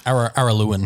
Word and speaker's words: Araluan: So, I can't pronounce that. Arulian goldfields in Victoria. – Araluan. – Araluan. Thank you. Yeah. Araluan.